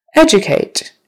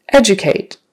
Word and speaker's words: educate: Educate. Educate. First syllable stress. And it's a verb, so educate.